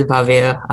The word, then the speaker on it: Bhavya: Hello, this is Bhavya. I've been pronounced B-H-A-V-Y-A and different variations of my name, so it's all good.